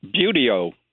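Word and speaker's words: Buteo: and it's spelled B-U-T-E-O. I've never heard that pronounced. How do you pronounce that? – A deep U, beauty-o. – Beauty-o. – So, beauty-o. – Beauty-o. – Awesome, okay. – As in beautiful beauty-os.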